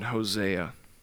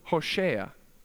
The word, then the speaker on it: Hosea: It's actually pronounced in the Hebrew Hosea. It's the very same name, Hosea.